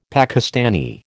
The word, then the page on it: Pakistani: American English